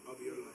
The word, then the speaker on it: alveoli: In our lungs, we have roughly 300 million alveoli. How do you say that? Alveoli.